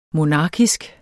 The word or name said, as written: monarkisk